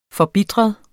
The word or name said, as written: forbitret